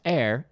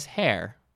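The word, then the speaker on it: heir: I used to pronounce air as hair. I used to pronounce that H.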